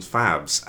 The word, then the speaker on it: fav: Yeah, you got like 5,000 favs and 12 retweets. That's weird. Have a blue tick. – Is it okay to pronounce it fav? – Fav. – Like fava bean.